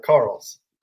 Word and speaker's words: Carrolls: How do we pronounce the last name? – Close. It's Carrolls.